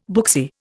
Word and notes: booksy: Indian

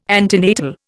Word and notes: antenatal: Indian

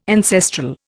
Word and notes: ancestral: Indian

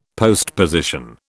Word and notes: postposition: British English

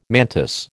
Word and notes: mantis: American English

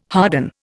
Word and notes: harden: Indian

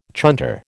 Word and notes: chunter: American English

